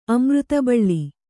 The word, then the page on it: ಅಮೃತಬಳ್ಳಿ: amřtabaḷḷi